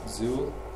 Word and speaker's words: XUL: XUL, pronounced XUL, stands for XML User Interface Language.